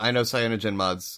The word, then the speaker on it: CyanogenMod: Yeah, and I know CyanogenMod's got a much bigger.